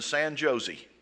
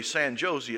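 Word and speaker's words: San José: He said, well, you know, out here, you know, it's not pronounced J, it's pronounced H. So it's not really San Jose, it's San Jose.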